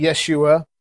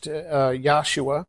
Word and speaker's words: Yahshua: A lot of the Messianic, you'll see Yeshua, or it might be more properly pronounced Yahshua.